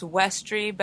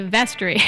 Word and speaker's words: Desiderium Vestri: Yeah, a man who studied Latin told me it's Westry, but it has a V. – Westry. So we're going to say Vestry.